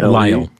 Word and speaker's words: Lisle: The author's name is Jason, I'm not certain of the pronunciation, L-I-S-L-E. – Lyle, Jason Lyle.